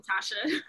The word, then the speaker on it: Tasha: So just call me Tasha. – Well, Tasha's not a long name to say. – Exactly. It's Tasha. One, they're two syllables.